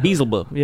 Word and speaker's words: Beelzebub: I think it's Beelzebub or something like that. – Beelzebub. – Yeah, exactly. That's how it's pronounced in the original Latin.